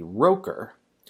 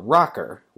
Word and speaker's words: Motorola Rocker: and I'm going to pronounce it as the Roker. Since I released this episode this morning, eagle-eared listeners have pointed out that it wasn't pronounced the Roker, it was the Motorola Rocker, which, now that this has been pointed out to me, makes a ton more sense.